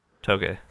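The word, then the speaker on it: tōge: The it's not is it Toge? – Toge. – Toge. Toge. Thank you.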